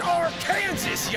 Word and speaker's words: Arkansas: He is an author and comedian who's been doing stand-up for over 20 years all over the United States and Arkansas. – It's pronounced Arkansas, you idiot!